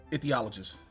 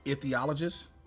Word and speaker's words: ichthyologist: ICHTYOLOGIST. – THY. – THY, excuse me. Ichthyologist. – Say that one some more. – Ichthyologist.